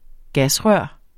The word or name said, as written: gasrør